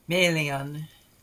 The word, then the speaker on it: Melian: Question of why Melian has accent on her first syllable, while Gil-galad has accent on the second syllable.